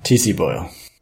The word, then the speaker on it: T.C. Boyle: How do you say that? – T.C. Boyle. – Yes, T.C. Boyle.